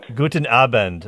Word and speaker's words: Guten Abend: And by the way, I should say to you, Guten Abend. Did I say that right? – Guten Abend. – Okay. – Guten Abend. Right.